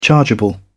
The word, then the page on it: chargeable: British English